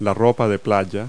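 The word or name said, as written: beachwear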